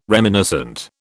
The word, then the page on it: reminiscent: British English